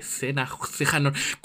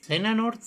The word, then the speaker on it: Xehanort: Sí, creo que esta es la historia de la saga de Xehanort. ¿Cómo se pronuncia el nombre? Se escribe... perdona. Se escribe... ¿Cómo pronuncia eso? – Kachiwane, no tengo idea. – Eso es racista, pero está bien. – Xehanort.